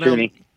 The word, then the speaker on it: Tunney: Robin Tunney, how do you say her? She went Tunney.